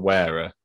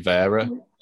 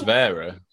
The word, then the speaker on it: Wera: I had it when I used to say, obviously, you got Wera, but it's actually Vera. – You had Wera. – You had a guy on here the other day that pronounces it like that, and he genuinely says it like that. – Yeah, it is Vera.